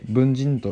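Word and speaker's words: 分人: でこの文人っていうのは分ける人と書いて文人と読みますね。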